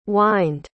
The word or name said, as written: whined